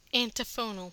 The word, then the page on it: antiphonal: American English